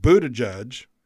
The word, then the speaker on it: Buttigieg: Mr. Buttigieg, I got it right there.